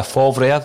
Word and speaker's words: Favre: We spoke about Lucia Favre, that's how you pronounce it, Declan, former Borussia Dortmund manager.